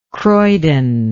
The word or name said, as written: croydon